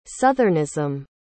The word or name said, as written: southernism